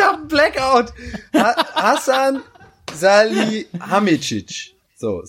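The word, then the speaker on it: Salihamidžić: Also, Hassan Salih, äh, Sali, Salih, sag mal, wie der heißt. – Salihamidzic. Salihamidzic, genau, genau. So, deswegen kam ich gerade durcheinander. Ich hab's falsch gesagt.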